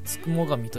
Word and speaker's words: 付喪神: が宿ると考えられていたのであのような絵画ができたとされていますちなみにこのつくも神なんですが漢字の方は、えっと、付録の付これでつくですねそしてもはもちのもそして神と書いてつくも神と読むんですが表記がもう一つあって、これは、えっ、ー、とですね、 99 の神でつくも神と読んだりもしています。